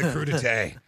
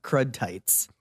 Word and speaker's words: crudité: God, Connie, she gave me the crudité. Oh, God. – Well, it's pronounced crudité, but if you saw it, it looks like crud tights.